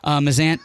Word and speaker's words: Mazzant: Mazant has two Z's like in pizza, M-A-Z-Z-A-N-T. Sorry, I get mad when people, I'm like, Joey Mazant, they're like, spell it, and I'm like, J-O, no, your last name.